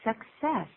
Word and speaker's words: success: I wish you success. I wish you success. 请注意两个 C，两个 C 有不同的发音，第一个是克，第二个是四。